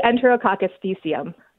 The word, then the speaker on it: Enterococcus faecium: What is the correct pronunciation? Go ahead. – We say Enterococcus faecium. – Okay, so I missed an extra O in there.